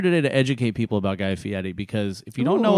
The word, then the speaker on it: Guy Fieri: His name is Guy Fieri.